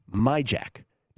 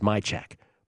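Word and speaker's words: Majchrzak: But I'm going with Kamil, I'm going to try to say this right, Majak. Now, it's not spelled that way. It's spelled like it's Majchurzak. It's pronounced Majak. But Kamil Majak is about, I got it at plus 168 last night against Filip Krajinovic.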